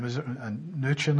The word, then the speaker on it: Mnuchin: And what's interesting about Steve Mnuchin, I can't pronounce his name, Mnuchin, I suppose is how you pronounce it, he is a hedge fund manager who is completely anti-Glass-Steagall.